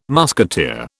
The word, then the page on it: musketeer: British English